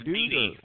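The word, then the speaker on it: Dede: We've got Deed Westbrook. Is it Deed Westbrook? Is that how you say it? Deed or Deed? – It's Deedy Westbrook.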